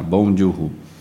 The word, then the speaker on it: Bong Joon-ho: Sim, esse é o Bong Joon-ho. Se eu não falei certo, eu vou falar agora. Bong Joon-ho.